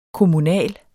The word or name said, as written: kommunal